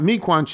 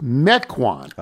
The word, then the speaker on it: Mequon: Tony, to help you out, Mequon should be pronounced Mequon, not Mequon.